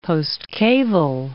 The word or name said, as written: postcaval